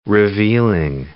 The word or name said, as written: revealing